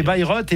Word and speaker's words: Bayreuth: ils ont dit Bayreuth alors que c'est Beyrouth. Non, c'est Bayreuth et Bayreuth, quand on le prononce comme ça, c'est où Jean-Marc ? – C'est en Allemagne.